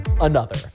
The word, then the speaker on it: ANOTR: A-N-O-T-R, also A-N-O-T-R. Wir haben einfach mal einen englischen DJ gefragt. – I have seen people call it another, a noter. I have been saying another, and I am so sure it's another.